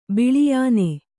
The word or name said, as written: ಬಿಳಿಯಾನೆ